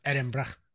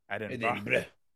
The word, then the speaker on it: Edinburgh: I believe it's pronounced Edinburgh. – Edinburgh.